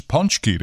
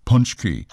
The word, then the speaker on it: paczki: Okay, it's spelled P-A-C-Z-K-I, magically pronounced Punchki.